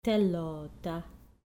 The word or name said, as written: thelo ta